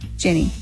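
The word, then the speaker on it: Jenee: So his name, it looks like, is Brody, and her name is Jenny? I may be saying that wrong. – Jenny. – It's J-E-N-E-E, so I could be saying it wrong, but Jenny. – Sounds like you got it right.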